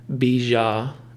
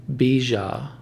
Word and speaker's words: bija: is Om Bija Namaha. So Om Bija Namaha. That middle syllable is B-I-J-A, if you need a visual.